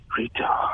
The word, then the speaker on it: Brito: Carlos Brito. – Yes, rhymes with Frito.